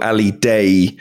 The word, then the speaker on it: Ali Daei: I'm going to go for the pronunciation is more Ali Daei, yeah.